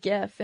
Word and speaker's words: gif: It's a really effective gif in a lot of situations. And yes, I do say it with a hard G.